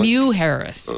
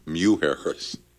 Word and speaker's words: Mujeres: Oh, New Harris, really? – Mew Harris, M-U. – Mew Harris.